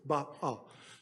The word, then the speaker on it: Baal: There was a god worshipped that was called Baal. That's how you say it. Baal.